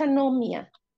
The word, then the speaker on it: anomia: Y cuéntenos, ¿qué es la anomia o anomía? No sé si lo pronuncio bien, anomia. – Sí, es anomia.